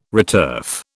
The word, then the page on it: returf: British English